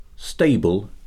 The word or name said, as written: stable